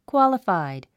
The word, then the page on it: qualified: kwάləfàid; クワラファイド